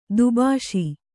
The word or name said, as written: ದುಭಾಷಿ